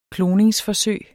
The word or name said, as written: kloningsforsøg